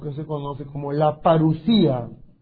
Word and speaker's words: parusía: La parusía. Parusía. Se escribe en griego así. Parousia. La parusía con una O ahí metida.